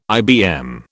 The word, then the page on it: IBM: British English